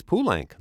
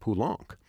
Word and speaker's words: Poulenc: And speaking of the French, do go ahead and pronounce the Z's in Berlioz and Boulez, and don't be fooled by the letters E-N-C. Francis Poulenc is Poulenc, not Poulenc.